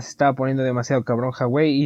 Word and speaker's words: Huawei: Ah, la pronunciación correcta es Huawei.